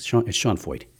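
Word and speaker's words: Sean Feucht: Sean Foyt, if any of you heard, spells his name S-E-A-N. F-F-T something, however he spells his last name. It doesn't look like it should be pronounced the way it is, but it's Sean Foyt.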